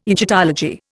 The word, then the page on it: Egyptology: Indian